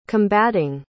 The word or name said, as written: combating